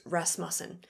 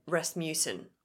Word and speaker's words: Rasmussen: My maiden name is Rasmussen, and people for some reason always said Rasmussen. – Like if they just read it. – If they just read it. – Yeah. – Even though it's not a split vowel. But I would spell it phonetically and put like in brackets afterneath and put in brackets underneath like mustn't.